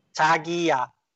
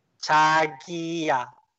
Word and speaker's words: 자기야: 자기야. 자기야. 자기야.